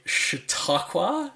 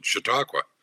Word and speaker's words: Chautauqua: So in 2013, you started the Chautauqua, is that correct? – Perfect.